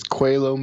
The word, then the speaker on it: Coelho: I pronounced that wrong last time, didn't I? How do you say it? – It's Coelho Medallion. It's a Portuguese name, which I stole from Paulo Coelho, who's the author of The Alchemist.